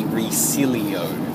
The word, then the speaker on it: Resilio: It looks like Resilo, but there's an extra I in it. So it seems to be Resilio, which is a bit silly.